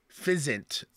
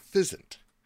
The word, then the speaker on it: Physint: It just I think you added a K sound in there. – What, yeah. How do you say it? – Fizzint. P-H-Y-S-I-N-T. – Okay, yeah, Fizzint.